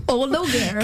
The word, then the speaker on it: O'Laoghaire: O-L-A-O-G-H-A-I-R-E. Like, Ologer. – Can you spell Ologer. It out again? – L-A-O-G-H-A-I-R-E.